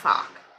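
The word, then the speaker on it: Foch: Fock? I don't know. It's F-O-C-H. I would pronounce that Fock. – Yeah. Nina Fock.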